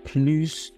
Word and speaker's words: plus: Donne-moi plus de temps, s'il te plaît. When you pronounce the S at the end of plus, it means more. When you don't pronounce the S, it means less.